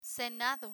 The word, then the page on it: senado: spanish